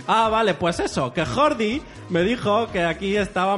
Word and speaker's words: Jordi: Si me asusté un poco el otro día que hablé con mi amigo catalán que tengo, mi amigo Jordi. Hombre, Mi- a veure, que la J no es pronuncia así en català.